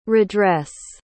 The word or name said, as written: redress